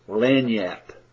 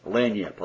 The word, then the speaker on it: Lagniappe: I will refer to these many infallible proofs as Lagniappe. Lagniappe, I'll spell it again. L-A-G-N-I-A-P-P-E. What is Lagniappe, you may ask?